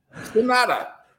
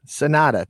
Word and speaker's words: Sanada: Sanada. – Sanada.